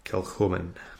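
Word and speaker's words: Kilchoman: And this time we are testing out a, how do you say that? – Kilhoman. – It's K-I-L-C-H-O-M-A-N.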